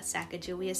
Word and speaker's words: Sacajawea: We say Sacajawea here at the Sacajawea Center, and we say Sacajawea because the Agaidika, Sacajawea's people, that is how they say it.